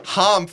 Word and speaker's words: Hom: There's no E. – Exactly, yes. We're going to go to Hom Furniture.